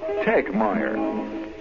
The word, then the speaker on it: Tegmeyer: He says the second T is silent. So it's Paul Tegmeyer.